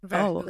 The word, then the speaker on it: Verhoeven: Verhoeven. Does that make sense? It's very Dutch.